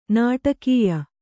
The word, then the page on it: ನಾಟಕೀಯ: nāṭakīya